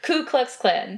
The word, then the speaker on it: Ku Klux Klan: Ku Klux Klan.